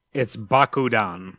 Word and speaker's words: Bakudan: It's Bakudan. – Bakudan. – Bakudan. – Bakudan. – How do you spell that? – B-A-K-U-D-A-N. – Wait. B-A-K-U-D-A-N? – Right?